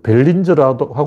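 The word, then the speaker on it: Barringer: 그리고 그 미국에 있는 벨링거, 벨린저라고 하고 벨, 링거라고 하더라고요. 영어 발음은 벨린저라는 소리는 있는데 베링거 운석공도 1.2km밖에 안 되고 이거는 초계는 8km야.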